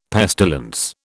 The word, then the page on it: pestilence: British English